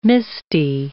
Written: misty

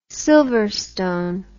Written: silverstone

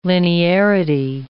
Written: linearity

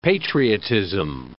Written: patriotism

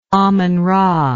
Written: amenra

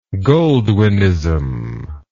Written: goldwynism

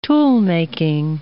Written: toolmaking

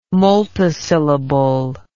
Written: multisyllable

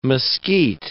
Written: mesquite

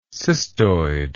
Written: cystoid

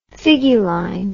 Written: figuline

